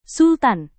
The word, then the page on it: sultan: Indonesian